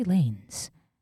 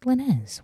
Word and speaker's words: Lanez: Also, I just realized I produced his name wrong. It's Tory Lanez. I said Lanez.